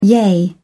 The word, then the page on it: yea: English; British English